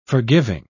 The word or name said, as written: forgiving